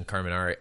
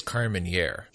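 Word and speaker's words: Carménère: And by the way, speaking of pronunciations in Carmenere, I think that we've been saying it wrong. I think it's Carmeniere.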